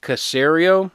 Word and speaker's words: Caserio: And that is the Houston Texans hiring Nick Caserio. Am I saying that correctly? – Yes, you are.